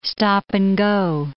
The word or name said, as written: stop-and-go